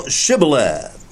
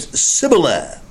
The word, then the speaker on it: Shibboleth: And if he said no, they would say to him, "Say now Shibboleth." But he said Sibboleth, for they could not pronounce it correctly, and they seized him and slew him at the fords of Jordan.